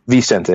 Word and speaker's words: Vicente: Is that Vicente? – Vicente, yeah. – Vicente, mm-hmm. The Italian-American in me wants to, like, pronounce all the C's as Ch, you know, Vicente, right?